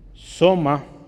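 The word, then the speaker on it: soma (σῶμα): La palabra es, eh, si la quisiéramos leer en español, es soma eh, con un acento en la O.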